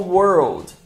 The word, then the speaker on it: world: E a dica para pronunciar a palavra world é separar em dois sons, duas palavras diferentes. A primeira é a palavra were, were. E a segunda é a palavra old, old.